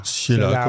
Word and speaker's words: Ciela: Bon, désolé d'avoir écorché votre nom, c'est Ciela, c'est ça? – Ciela, comme on veut, voilà, il n'y a pas de problème.